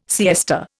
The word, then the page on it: siesta: Indian